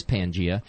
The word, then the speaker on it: Pangea: And I had the name wrong the other day. – Yeah. – It actually is Pangea, and it's called that.